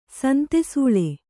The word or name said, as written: ಸಂತೆಸೂಳೆ